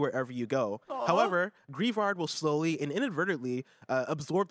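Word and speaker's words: Greavard: G-R-E-A-V-A-R-D. I'm going to say Grievard. Meet Grievard. – Grievard, yeah.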